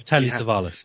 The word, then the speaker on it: Savalas: And of course, yes, Telly Savalas.